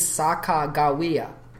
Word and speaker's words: Sacagawea: Everyone says Sacagawea. But it turns out because these guys were such terrible spellers and they didn't know the Shoshone language, they would spell things out phonetically. So when you read that Saka, you have to read it phonetically. It is Sacagawea. – Sacagawea. – Yeah. You just read it phonetically as it's spelled, and that's...